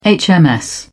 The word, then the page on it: hms: British English